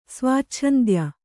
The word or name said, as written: ಸ್ವಾಚ್ಛಂದ್ಯ